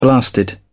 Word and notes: blasted: British English

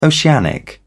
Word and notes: oceanic: British English

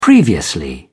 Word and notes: previously: British English

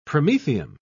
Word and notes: promethium: British English

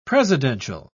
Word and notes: presidential: British English

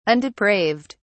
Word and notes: undepraved: British English

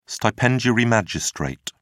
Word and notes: stipendiary magistrate: British English

